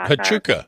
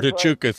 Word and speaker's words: Huachuca: There's an army base there, and I'll probably pronounce it wrong, but it's based Wichita or something like that. – No, Fort Huachuca.